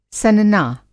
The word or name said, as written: सनना